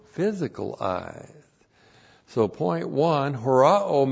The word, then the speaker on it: horao: H-O-S, relative pronoun host, whom. And then the verb horao. H-O-R-A long O. Horao.